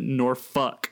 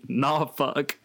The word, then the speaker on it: Norfolk: And it says here, first comment is, the majority of people in the area pronounce it Norfuck. Although you will also find locals who say, nah, fuck.